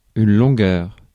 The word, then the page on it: longueur: [yn lɔ̃.ɡœʁ]; /lɔ̃.ɡœʁ/; /lɔ̃.gœʁ/